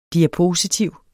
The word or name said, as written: DIAPOSITIVET